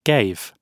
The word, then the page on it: gave: British English